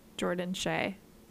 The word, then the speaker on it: Jordan Shea: Jordan Shea. – Yeah, no, okay. No, that's like Shea butter. – Yeah, or the stadium, one of the two.